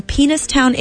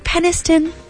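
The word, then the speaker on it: Penistone: The New York Times reports people in Penistown, England are quick to tell strangers, it's pronounced Peniston.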